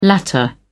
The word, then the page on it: latter: British English